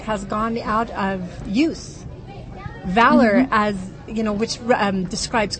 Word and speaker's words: Valor: Valor is spelled the American way, which is V-A-L-O-R. – And no, you do not pronounce it velour.